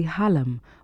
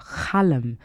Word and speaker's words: khalam: Among the traditional musical instruments in Senegal, probably the most common instrument is the halam, or khalam, which is the way Leo and I like to pronounce it.